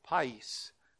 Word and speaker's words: pais: In our adult Sunday school class this morning, we looked at the word for the child, which is the word pais, P-A-I-S, we would spell it.